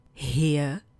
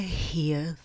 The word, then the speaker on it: here: Co nám tam tak trochu dělá neplechu je, jak už to v angličtině ostatně bývá, výslovnost, která u toho prvního slova zní where, u druhého here a u toho posledního there. Where, here, there.